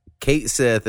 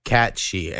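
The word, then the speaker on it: Cait Sith: A random fact I heard recently is Kate Sith is actually pronounced, like, it rhymes with Banshee. It's like Cat-she. And it's because in like their, when you watch their animated versions of this, he has a Scottish accent, Cat Sith. And I guess in Scotland, when it's spelt like that, it's pronounced like she. So we've been saying it wrong forever.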